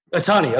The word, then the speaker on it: Ohtani: Hey, speaking of great hitters, what's a Tony? Am I pronouncing the name right? A Tony? He's the Japanese. – Shohei Ohtani. – Ohtani, okay, yeah, I was pronouncing wrong.